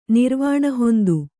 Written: ನಿರ್ವಾಣಹೊಂದು

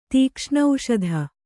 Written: ತೀಕ್ಷ್ಣೌಷಧ

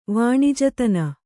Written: ವಾಣಿಜತನ

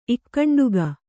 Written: ಇಕ್ಕಂಡುಗ

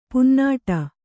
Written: ಪೊನ್ನುಡೆ

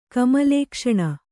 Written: ಕಮಲೇಕ್ಷಣ

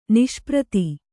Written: ನಿಷ್ಪ್ರತಿ

